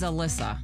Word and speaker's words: Alissa: My middle name's Alyssa. A-L-I-S-S-A.